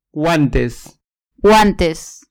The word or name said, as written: guantes